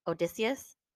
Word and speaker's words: Odysseus: And so basically in this novella, she's having an affair with Odysseus. – Yeah. – Did I say that right? – Yeah.